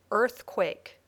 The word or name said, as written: earthquake